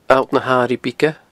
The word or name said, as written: alltnahairighebige